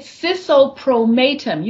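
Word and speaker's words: Sisohpromatem: It's Sis, and it's just our choice. It is metamorphosis spelled backwards. And we say Sisso Promatum. Some people try it and they say Sisso Promatum, which is okay. We just chose mate. M-A-T-E is in there.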